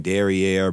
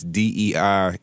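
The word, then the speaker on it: Dernière: It looked like Derriere, but it's Derniere.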